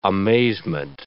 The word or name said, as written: amazement